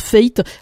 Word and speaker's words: F8: ça se prononce Fate, le destin en anglais, et de destinée, il en est souvent question dans les chansons de Five Finger Death Punch.